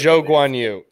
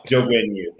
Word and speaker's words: Zhou Guanyu: Zhou Guanyu. Come on. – Zhou Guanyu.